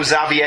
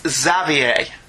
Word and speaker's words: Xavier: And for the love of all things holy, I know you Brits dislike the letter R, but it is pronounced X-Xavier. I know Xavier is French, but every multimedia form of X-Men pronounces it the American way.